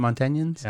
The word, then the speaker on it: Montanians: The bill also removes a number of Montana's gun-free zones and ends the unnecessary disarming of Montanians. Is that how you say it? Montanians? – Yeah, Montanians. Montanians.